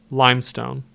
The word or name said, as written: Limestone